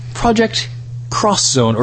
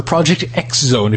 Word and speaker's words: Project X Zone: It was a project called Project Cross Zone, or Project X Zone, if you wish to pronounce it as it is written.